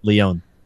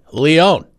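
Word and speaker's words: León: I always say Leon. I was like, that's not right. Leon. – Okay. Leon. Not Leo. Not like Leo DiCaprio.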